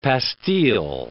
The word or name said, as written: pastille